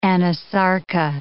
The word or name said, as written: anasarca